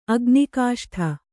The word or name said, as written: ಅಗ್ನಿಕಾಷ್ಠ